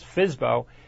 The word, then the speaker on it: FSBO: They're called uh, that FSBO, sometimes be pronounced as Fizbo, means for sale by owner.